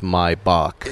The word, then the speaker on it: Maybach: Why do they not take the A out if they want it to be pronounced Maybach? – It's Deutsch. Maybach.